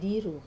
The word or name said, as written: diru